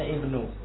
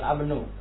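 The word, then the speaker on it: ibnu (ابن): Ya, ini artinya dibacanya ibnu. Ya, bukan abnu, tapi ibnu.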